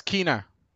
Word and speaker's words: Kena: The pronunciation used on the trailer was Kena.